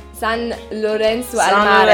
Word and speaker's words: San Lorenzo al Mare: San Lorenzo al Mare. – Ja, genau, du musst Italienisch aussprechen. San Lorenzo al Mare. – San Lorenzo al Mare.